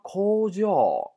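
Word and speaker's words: 工場: 那么这个单词单独拿出来的话呢，这个单词的读音要注意了，它读成 kojo 啊，是这样的，是第三个假名是高音 kojo 啊，这个单词读音很特殊，哎，有点特殊啊。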